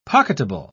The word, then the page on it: pocketable: British English